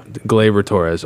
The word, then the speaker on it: Gleyber Torres: Gleyber Torres on the Yankees. – Let's not butchering his name. – Did I say it right? – You did say it right.